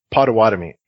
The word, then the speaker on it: Potawatomi: The proper pronunciation is Potawatomi.